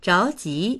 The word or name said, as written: zhao2ji2